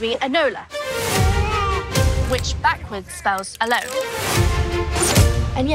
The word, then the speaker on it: Enola: We keep accidentally pronouncing it Ilona Holmes, but we're determined not to do that tonight. Enola. Tamsin? – Enola.